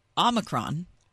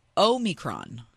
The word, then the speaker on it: Omicron: Okay, so Omicron is there are two different ways you can pronounce it. You can pronounce it Omicron, or you can pronounce it Omicron.